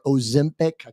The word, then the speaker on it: Ozempic: There's two main ones, and they are called scientific names are semaglutide, which is Olympic or Ozempic.